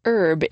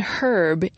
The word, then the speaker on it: herb: For example, the word for a certain kind of plant is pronounced herb in American English and herb in British English.